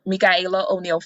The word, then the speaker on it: Michaela: Friends and family call me Shayla, but my first name is actually Michaela, and it's spelled M-I-C-H-A-E-L-A. It looks like Michael with an A. Michaela O'Neal Farmer.